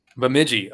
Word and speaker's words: Bemidji: So is it Bemidji State? Is that how you pronounce the university? – Bemidji.